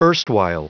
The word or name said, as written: erstwhile